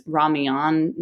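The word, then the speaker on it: ramyeon (라면): Their ramen, which is ramyeon, not even, it's not pronounced like the Japanese style,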